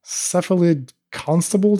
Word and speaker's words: Cephalid Constable: And another list that was even weirder was like a Bant Cephalid Constable deck. Am I saying this right? – Yeah. – And even just like uttering these words, you know you're in for some nonsense. Cephalid Constable, one blue blue, Cephalid Wizard.